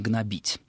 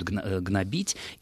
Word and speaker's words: гнобить: А вот в орфографическом я нашел ударение «гнобить». Но при этом интересно, что на достаточно многочисленных интернет-ресурсах, посвященных вопросам русского языка, время от времени это слово всплывает, и очень часто пользователи интернета спорят о том, даже куда ударение ставить. Я в своей жизни тоже слышал, как «гнобить», но вот орфографический словарь дает «гнобить», и думаю, что перед нами пример того, что достаточно часто характеризует слова, скажем так, научно-субстандартной лексики.